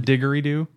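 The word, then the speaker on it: didgeridoo: Diggery Doc? – Huh? – Wouldn't it be Diggery Doc? – However you pronounce it. – Okay. – Is it Diggery Doo? – Yeah, it is.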